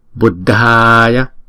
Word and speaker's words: Buddhaya: Chữ D đứng một mình chúng ta đọc là D. Chữ D H là D. Buddhaya.